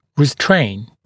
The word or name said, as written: restrain